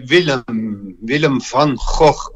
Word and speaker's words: Willem van Gogh: Soy Willem van Gogh, como se pronuncia en holandés.